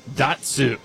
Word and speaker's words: Datsyuk: Happy birthday, Pavel Datsyuk.